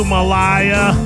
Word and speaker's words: Malaya: Dark Like You, Malaya. Rhymes with papaya.